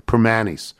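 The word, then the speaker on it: Primanti's: Ask any local to pronounce Primantes and they will say Primanis.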